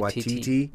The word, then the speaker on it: Waititi: So it's based on the 2014 film of the same name by uh, Taika Waititi, Waititi yep.